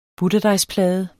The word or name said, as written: butterdejsplade